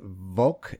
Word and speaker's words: Vovk: Welcome to episode 17, I think, of Something New Every Week with Aaron and Elizabeth Vovk. Am I saying it correctly? – Vovk, you got it.